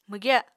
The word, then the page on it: mwgya: Apatani